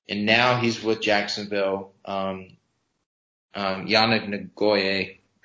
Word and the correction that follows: Ngakoue: I can't pronounce his last name. – Ngakoue. yeah, Ngakoue.